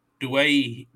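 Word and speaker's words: Doueihi: And from what Andrew Voss was teaching me, it's Adam Dwayhe. – Dwayhe. – And I've been saying Dewey this whole time.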